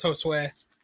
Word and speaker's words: Josue: Is that that how you say his name? – Yeah, it's Josue.